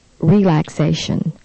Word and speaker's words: relaxation: Relaxation. The third syllable receives the stress, so you should circle three.